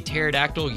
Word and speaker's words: pterodactyl: Have you ever heard why you can't hear a pterodactyl using the bathroom? – No, I haven't really thought about that. – Because the pee is silent.